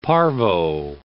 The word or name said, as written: parvo